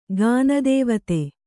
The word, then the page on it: ಗಾನದೇವತೆ: Kannada